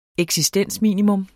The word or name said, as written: eksistensminimum